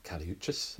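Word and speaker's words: Kali Uchis: Can you spell rather than pronounce? – Yes, K-A-L-I space U-C-H-I-S. – Okay. – I was close. – Yeah, Kaliuchis sounds, sounds close.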